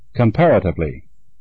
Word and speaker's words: comparatively: The first syllable, work, W-O-R-K, is correct. – Comparatively. – The second syllable, pair, P-A-R, is correct. – Surfaces. – The first syllable, sir, S-U-R, is correct.